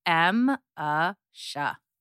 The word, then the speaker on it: Emese: And so I always tell people it's M-A-Sha.